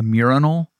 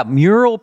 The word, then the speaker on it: mural: And he mistakenly pronounced it a murinal? – Yeah, mural being pronounced as murinal became a big, you know, that was a big writer's room bit, I think, that we were excited about for the show.